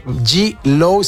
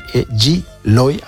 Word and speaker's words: Gweloi: G-Loy, si G-Loy, perdón, se escribe G-W-E-L-O-I latina. G-Loy, así es el nombre de este proyecto, que la verdad está bastante chido, punxito, ramonoso, y pues bastante energético con este trío de carnales.